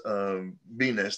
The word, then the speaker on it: Bines: Actually, it's called Bines, my last name. So my Hebrew last name. – Okay. Got it. – So it's Bines, the same thing. It's pronounced a little differently.